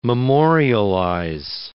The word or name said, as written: memorialize